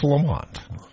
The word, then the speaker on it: Lemont: You know, some people pronounce it Lemont. – Yes, people that are incorrect in what they want to say do pronounce it that way, but it's Lamont.